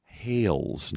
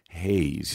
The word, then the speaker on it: Hales: But I rushed and I dropped the L. So it's Ashley Hales, not Ashley Hayes.